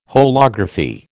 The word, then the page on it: holography: American English